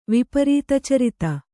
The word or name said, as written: ವಿಪರೀತಚರಿತ